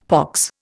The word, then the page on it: pox: Indian